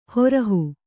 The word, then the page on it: ಹೊರಹು: horahu